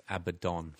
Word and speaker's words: Abaddon: Abanions? – No, it's Abanions. It's pronounced Abaddon, we learn.